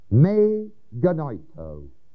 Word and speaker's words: me genoito: The Greek words used in every text are first may, genoito, M-E, long E, next word, G-E-N-O-I-T-O. Me genoito.